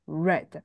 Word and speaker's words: read: I have red hair，red 才会是红色，你的 read 才会是阅读，只是你的阅读，它的过去式才会变成 red，read，red。 Red，所以这两个字的发音还是要把它分清楚。